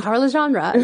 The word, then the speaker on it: Bokara Legendre: And I sent her to, I'm going to say her name wrong, Bokara Legendre. – Bokara Legendre. Yeah, yeah, that's good.